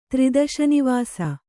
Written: ತ್ರಿದಶನಿವಾಸ

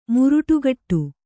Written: ಮೊರಡುಗೋಡು

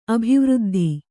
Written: ಅಭಿವೃದ್ಧ

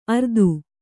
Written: ಅರ್ತು

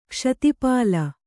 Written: ಕ್ಷಿತಿಪಾಲ

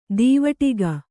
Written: ದೀವಟ್ಟಿಗ

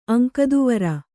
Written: ಅಂಕದುವರ

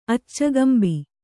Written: ಅಚ್ಚುಗಂಬ

